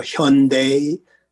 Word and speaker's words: Hyundai: This should be like a Hyundai, you know, many people say Hyundai.